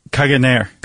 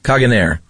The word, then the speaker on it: Caganer: How do you pronounce this? The Caganer? – Caganer. – Caganer. – Yeah, pretty close.